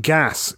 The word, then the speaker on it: geas: But the I believe it's pronounced gas. It's an Irish word originally. – Yeah, G-E-A-S. But then you think about like geese.